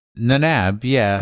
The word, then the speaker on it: Nanab: At Pokestops, Nanabib berries and Pinip berries. I don't know how to say this. Nanab. – Is it not? – Nanab, yeah.